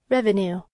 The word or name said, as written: revenue